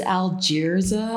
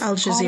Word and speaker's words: Al Jazeera: There's this one site, is Al Jazeera, I think it's Al Jazeera. – Oh, I know I'm mispronouncing it. Yes. Al Jazeera.